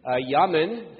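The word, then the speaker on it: Jamin: Nemuel, found later in Europe as the Nemeti, Yamin, and I'll pronounce the J as a Y, as it would be in Hebrew, and Yamin is the name of his son in Numbers.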